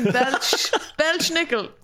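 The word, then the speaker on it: Belsnickel: Sure, I have the pronunciation Belschnickel. So I am pronouncing it right.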